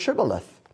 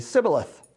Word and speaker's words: Shibboleth: And they did it by figuring out their accent and had them pronounce the word Shibboleth. And apparently, they could not say the SH sound. So they would say Sibboleth.